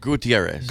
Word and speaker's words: Gutierrez: Gutierrez.